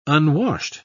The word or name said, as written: unwashed